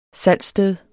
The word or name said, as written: salgssted